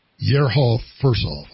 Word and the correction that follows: Yehor Firsov: I'm not intentionally mispronouncing his name, Yehor Firsov, Fursov, is a combat paramedic in the Ukrainian army, and he's a former member of parliament.